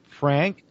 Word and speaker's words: Frank: Frank, and the last name is spelled J-O-R-G-E. F-R-A-N-K is the first name.